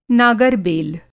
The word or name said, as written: नागर-बेल